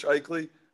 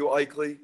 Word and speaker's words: Aichele: Yeah, I'm just on Twitter at Coach Eichle. Last name is spelled A-I-C-H-E-L-E there. And then you guys can email me too. My email is J.W. Eichle, A-I-C-H-E-L-E at O-W-U.E-D-U, Ohio Wesleyan University.